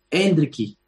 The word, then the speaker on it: Endrick: Rodrigo ha dicho Endricky, con acento en la primera E.